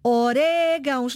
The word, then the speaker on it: orégãos: Órgão, orégãos, não está na penúltima. Deve ter acento porquê? Em órgão, acento no O. Orégãos, acento no E.